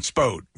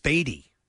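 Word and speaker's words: Spoede: It was spelled S-P-O-E-D-E, I think. – Spode. – Right. No, it's pronounced Spadey.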